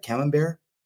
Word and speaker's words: camembert: And, do you pronounce that camembert? – Yes.